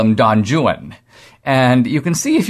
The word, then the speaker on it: Don Juan: Byron wrote a work called Don Juan, but in Byron's day it was actually pronounced literally Don Juan.